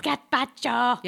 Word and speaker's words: gazpacho: What uncooked cold vegetable soup is found on Spanish restaurant gazpacho. – Menus? Gazpacho.